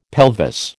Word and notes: pelvis: American English

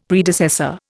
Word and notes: Predecessor: Indian

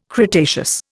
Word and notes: cretaceous: Indian

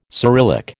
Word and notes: Cyrillic: American English

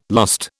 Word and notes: lust: British English

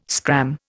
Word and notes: scram: Indian

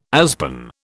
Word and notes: aspen: British English

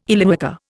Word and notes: Illywhacker: Indian

